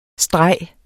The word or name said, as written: streg